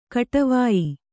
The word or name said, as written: ಕೊಡುವಾಯಿ